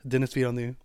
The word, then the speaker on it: Denis Villeneuve: I loved Denis Villeneuve. He's French, so I could be pronouncing that wrong. – It rolled off the tongue very nicely.